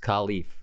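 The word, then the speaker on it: Kalif: I can't remember who it was on the Fox crew, but game one of this year, they said, you know, the big emphasis on him being pronounced Khalif this year.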